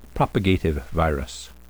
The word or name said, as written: propagative virus